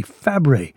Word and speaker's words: Fabray: In fact, her aunt was the actress Nanette Fabre, who officially changed the spelling of her own last name to F-A-B-R-A-Y, so people would pronounce it correctly, Fabre.